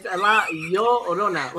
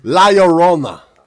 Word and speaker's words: La Llorona: La Llorona? – You got to work on it. It's La Llorona. Like, yo. – La Llorona. – There you go. There you go. – Jesus. – La Llorona.